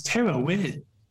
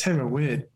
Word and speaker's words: Theravada: So that's kind of it's actually pronounced, I only found out this reason, it's Theravada. Now, why it's spelled that way, I have no idea. But this is classic, isn't it? – Right. – I've been pronouncing it in exactly the same way, but in Thailand, it's Theravada.